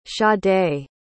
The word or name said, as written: sade